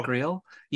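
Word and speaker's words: Griot: Is it Greel or Greel? Yeah, it's G-R-I-O-T, but it's pronounced Greel.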